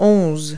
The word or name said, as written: eleven